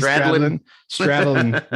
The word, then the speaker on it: Stradlin: Stradlin? I don't know. – That's right. – Izzy Stradlin.